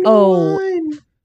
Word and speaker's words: Noein: Noein.